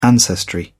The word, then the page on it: ancestry: British English